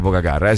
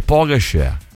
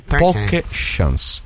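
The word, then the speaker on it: Pogačar: Poi non si dice Pogacar, si dice Pogacar. – Secondo me si dice poche share, si dice poche chance.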